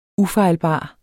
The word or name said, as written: UFEJLBART